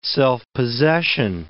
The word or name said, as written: self-possession